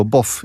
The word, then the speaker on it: Bov: Mal so, Bov. Ich habe dann irgendwann festgelegt, dass ich mich Bov ausspreche, weil es ja ohnehin egal ist.